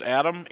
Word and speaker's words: Adam: Yep, first name is Adam, A-D-A-M. Last name is Jewett-Pierzo. It's spelled J-E-W-E-T-T hyphen P-E-R-R.